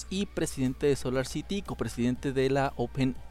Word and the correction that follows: OpenAI: No sé cómo se pronuncia eso. – Eh, de hecho, es OpenAI, güey. O sea, como inteligencia artificial.